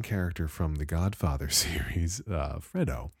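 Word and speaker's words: Fredo: Now I have to pause and make sure that I'm pronouncing the name right. 1 second. Okay, careful watching of a YouTube video suggests that Pacino really pronounces it Freddo. He kind of muffs the E a little bit there in the middle. We'll go with Freddo.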